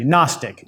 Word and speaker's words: Gnostic: Gnostic. It's spelled G-N-O-S-T-I-C. Gnostic.